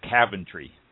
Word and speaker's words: Coventry: but I've always pronounced it Coventry. He pronounced it Caventry.